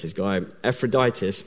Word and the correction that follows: Epaphroditus: I think that's how you pronounce his name. Is that right? Epaphroditus.